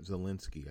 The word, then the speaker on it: Zelensky: He's getting impeached over a conversation that apparently the president had with President Volodymyr Zelensky.